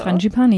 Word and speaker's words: Frangipani: Frangipani? Spricht man das so? – Frangipani, aus? – Ja.